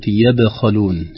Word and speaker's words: يبخلون: الآية الأولى فيها كلمة يبخلون، هذه الكلمة فيها تنبيهان: الأول في قلقلة الباء الساكنة، لأنها من حروف القلقلة الخمسة قطب جد، إذا سكن أحد هذه الحروف وجب أن يظهر في النطق باضطراب مخرج الحرف يا باخالون. يتلوها الخاء المفخمة باستعلاء أقصى اللسان خوف من أن تكون يا باخالون هكذا، الصحيح يا باخالون قلقلة بعدها تفخيم.